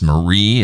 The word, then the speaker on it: Mree: Her name is Marie. It's spelled M-R-E-E.